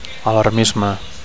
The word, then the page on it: alarmisme: Catalan